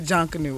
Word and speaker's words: Junkanoo: So, I never knew what junkanoo was. – Okay. – I hope I'm saying it right. – Yeah, junkanoo. Yeah, you got it.